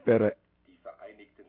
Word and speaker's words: Vereinigten: D-I-E space. V-E-R-E. I-N-I-G-T-E-N. And see the, and then Staaten, S-T-A-A-T-E-N. V is pronounced F in the German form, you know what I'm saying? So, like when you say four, you actually be saying fear.